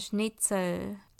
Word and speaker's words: Schnitzel: 答案就是 Schnitzel，炸猪排。这个字也是德文来的。那它在德文跟英文里面的拼法是一样的，不过发音有些微的差异。德文是。 Schnitzel，Schnitzel。那英文的话呢，就比较常会听到 Schnitzel，Schnitzel。